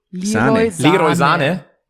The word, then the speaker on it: Sané: Ihr sprecht es halt alle falsch aus. Ihr müsst es halt aussprechen wie Jogi Löw. – Leroy Sané.